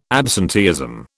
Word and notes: absenteeism: British English

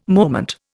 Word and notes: moment: Indian

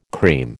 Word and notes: cream: American English

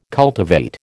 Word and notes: cultivate: American English

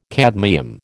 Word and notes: cadmium: American English

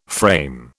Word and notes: frame: British English

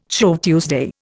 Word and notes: Shrove Tuesday: Indian

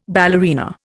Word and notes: ballerina: Indian

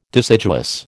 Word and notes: deciduous: American English

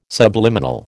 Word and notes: subliminal: American English

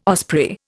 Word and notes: osprey: Indian

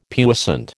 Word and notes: puissant: American English

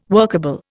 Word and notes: workable: Indian

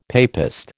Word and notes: papist: American English

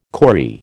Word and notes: kauri: American English

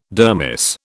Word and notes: dermis: British English

